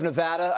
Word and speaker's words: Nevada: Am I saying that correctly, Mr. Rove, Nevada? I think I am. – Yes.